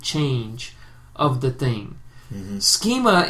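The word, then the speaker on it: schema (σχῆμα): Morphe and schema. Did I say that right? Yeah, morphe and schema.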